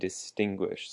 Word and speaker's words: distinguish: Distinguish has the emphasis also on the second syllable. Distinguish.